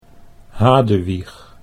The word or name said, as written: Hadewich